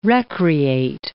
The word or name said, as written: recreate